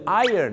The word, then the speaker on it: iron: ก็จะเป็นเสียงคล้ายๆกับคำนี้ครับ iron ก็คือ iron นั่นเองครับแต่ไม่ต้องถึงขนาดเหยินเหมือนที่ผมออกเสียงนะครับ iron นะฮะ iron นะฮะ iron นะครับผม